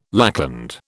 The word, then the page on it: lackland: British English